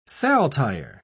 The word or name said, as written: saltire